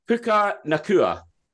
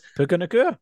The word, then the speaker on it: Puka Nacua: Puka Nacua. – No, you got it. Nailed it. Puka Nacua, there you go.